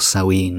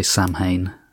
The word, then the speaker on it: Samhain: It is Gaelic, and I've checked the pronunciation on a number of different websites, and it seems that Samhain or Samhain is the most common pronunciation. – Ah, but yeah, okay. you do hear people say Samhain, and usually that means they haven't looked up how to pronounce it.